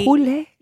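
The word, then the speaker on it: Hole: Yul Nesbo's Detective Hule. Hulle. Yeah, it's spelt Hull. I'm trying to talk a bit Norwegian.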